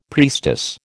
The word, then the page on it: priestess: American English